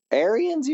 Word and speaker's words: Arians: Arians, you mean? – Yeah, Arians, whatever his name is. – Yeah. Don't fucking mispronounce that guy's last name. It's Arian, like Arian Brotherhood.